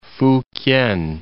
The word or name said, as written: Fukien